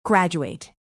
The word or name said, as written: graduate